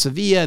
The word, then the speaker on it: Sevilla: It was a little town called Seville, called pronounced Sevilla, and then traveled around for about a month at uh, one semester in college.